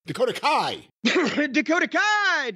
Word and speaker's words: Dakota Kai: Dakota Sky. Dakota Kai. – Dakota Kai. Do you have to say it like the way Dakota Kai? – You have to say Dakota Kai.